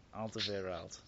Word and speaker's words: Alderweireld: Alderweireld. – Big Tobes. – Big Tobes. He kept calling him Big Tobes. I was thinking, what's going on? That was spot on. Alderweireld.